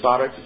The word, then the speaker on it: sarx: So Jesus incarnated in John chapter 1, verse 14, and the Greek word there, if I'm not mistaken, is sarx, something to that nature, S-A-R-X is pronounced.